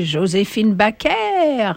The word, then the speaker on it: Joséphine Baker: Bon, maintenant nous allons écouter Joséphine Baker. Of course, I'm pronouncing her name the French way. It's Joséphine Baker, but in France, everybody called her. Tout le monde disait Joséphine Baker, pas Baker.